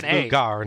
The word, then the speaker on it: Luger: Pronounced as Luger, not Luger.